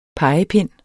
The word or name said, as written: pegepind